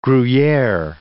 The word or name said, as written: Gruyere